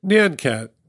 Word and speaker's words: Nyan Cat: Nyan Cat.